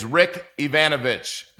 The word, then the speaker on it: Rick Yvanovich: I'm your host, and with me today is Rick Yvanovich. Did I say that right, Rick? – Yeah, you did.